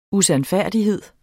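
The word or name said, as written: USANDFÆRDIGHED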